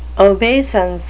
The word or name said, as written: obeisance